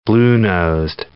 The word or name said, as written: bluenosed